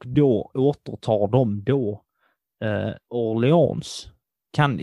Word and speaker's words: Orléans: O-R-L-E-A-N-S. Och sedan en liten apostrof. – Jag hade nog inte sagt s bara. Orléans.